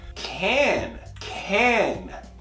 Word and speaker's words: can: Esse verbo ele pode ser pronunciado de duas formas. Com a maneira mais forte, que seria esse é, can, can. Eu posso, I can. Ou com o schwa, que é a maneira mais fraca.